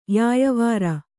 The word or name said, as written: ಯಾಯಾವರ